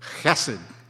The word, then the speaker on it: chesed: chesed.